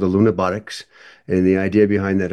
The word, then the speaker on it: Lunabotics: I was going to ask you about the NASA Lunabotics Challenge. I think I'm saying that right. Can you tell me about it? – That is correct, and that's what Oliver was talking about.